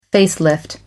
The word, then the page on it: facelift: American English